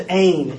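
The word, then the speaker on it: ein (ἦν): It's pronounced ein in the Greek.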